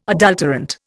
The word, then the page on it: adulterant: Indian